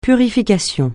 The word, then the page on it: purification: francais